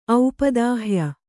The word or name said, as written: ಔಪದಾಹ್ಯ